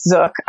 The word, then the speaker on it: Zook: He pronounces it like book. It's Zook. And then Kimra Lunas.